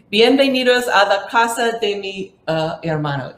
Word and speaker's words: bienvenidos a la casa de mi hermano: So, bienvenidos a la casa de mi hermano. Did I say that right? – Yeah, you did.